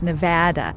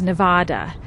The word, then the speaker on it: Nevada: The proper pronunciation is Nevada. – That's what I meant. Nevada. – With the A vowel as in cat. – Ah. Ah. Ah. – The incorrect pronunciation is Nevada, with the vowel as in cot.